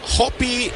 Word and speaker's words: Hope: Hopi es la pronunciación también de este pitcher. Se escribe Hope.